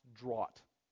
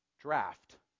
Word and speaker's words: draught: We were sitting in a game, and I saw a word, draught, and I thought that it was pronounced draught. Now, if you don't know, that word is pronounced draft. Why? Because it's British.